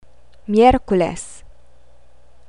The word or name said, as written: miyerkules